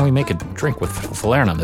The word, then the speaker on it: falernum: And the next one is falernum. Did I say it right? Well, we're going to hear from an expert how to say it. – Falernum. Or known as velvet falernum.